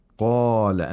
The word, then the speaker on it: قَالَ: The first one is the qaf, because it's a letter of isti'la. It has the characteristic of isti'la, which means the root of the tongue is raised while pronouncing this letter. قَالَ, and the mouth opens vertically, too. And this is because the letter is followed by an aliful mad.